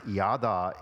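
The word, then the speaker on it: yada (ידע): In most English Bibles, the Hebrew verb that is pronounced yada is rendered acknowledge.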